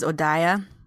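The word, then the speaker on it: Odeya: Her name is Odaya.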